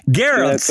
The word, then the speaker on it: Garrelts: Well, Chef Colby, and I'm going to butcher his name one more time, Garrelts.